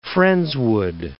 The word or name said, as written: Friendswood